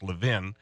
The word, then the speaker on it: Levin: Some people call him something else, but it's pronounced Levin.